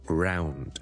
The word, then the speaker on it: ground: 再加个 d 喺后面变咗 ound ound ound，好，前面再加到个 r 音变咗 round round，呢个咧就系 round 嘅发音，一个圆圆嘅东西，round，it's round 圆嘅 adjective round，再加多个 g 喺前面咯，ground ground ground ground，I'm going to the ground floor，it's on the ground floor，即系佢系喺地下嗰层嘅 ground，咁所以呢个字其实系唔系好容易读嘅，唔系关唔系 ground，it's ground n 音 ground。